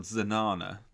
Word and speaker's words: Zanana: The last top one, which we still don't know why it was called that, was called Zanana, to rhyme with banana.